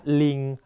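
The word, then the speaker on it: linh: แต่ถ้าตัวเลขศูนย์วางอยู่ในหลัก 10 นะครับหลักเดียวจะออกเสียงว่าลิงหรือแหล่นะฮะยกตัวอย่างเช่น 102 นะครับจะออกเสียงว่าโหมดจำลิงไฮนะ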